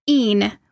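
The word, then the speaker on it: e'en: And evening, but evening has been truncated to een, which is spelled E-apostrophe-E-N.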